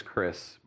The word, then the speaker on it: Khris: My name is Khris, but it's spelled K-H-R-I-S.